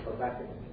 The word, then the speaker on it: Habakkuk: Habakkuk. – Oh, all right. Habakkuk.